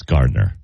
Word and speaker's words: Gardiner: How did always, I pronounce it? Well, Gardiner? – Probably, because that's how it's spelled. – That's Gardiner. – Gardiner.